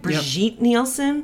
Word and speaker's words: Brigitte Nielsen: Because Brigitte, is that how you say it? Brigitte Nielsen? – Mm-hmm.